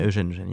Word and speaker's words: Eugène: So we've got new French brasserie, Eugène. Is that how am I saying it right? – Yeah, Eugène, yeah.